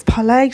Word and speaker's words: pale: For we wrestle, in the Greek, this word is pole, spelt. Sorry, it's spelled P-O-L-H-E-Y, but it's spelled P-A-L-E.